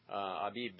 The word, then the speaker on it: Abib: We'll call it Abib, or Abib, as we usually say it. Abib is how it would be pronounced in Hebrew.